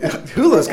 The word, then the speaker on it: Hulu: And while you're sitting there and, you know, I mean, there's only so much Netflix and Hulu. Did I say it right? – Yes, Hulu.